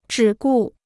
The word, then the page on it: 只顾: zhǐ gù